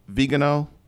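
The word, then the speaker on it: Viganò: About with Archbishop Vigano.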